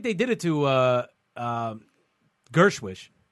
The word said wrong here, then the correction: Gorsuch: Did they? – Gorsuch? – How do you say his name? – Gorsuch? – Yeah.